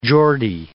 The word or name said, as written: Geordie